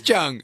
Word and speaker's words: Jung: So it's not Chung. I thought it was Chung, but it's actually... I don't know, it is Chung. – It is Chung. Okay, it's Chung.